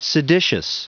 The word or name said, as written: seditious